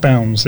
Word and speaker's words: Bownds: Your name is B-O-W-N-D-S. – Correct. – And nobody ever says Bounds. – Like, people do. Yeah. They're pronounced Bounds, yeah. – It's Bounds.